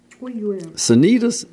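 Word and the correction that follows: Cnidus: Okay, Nidus.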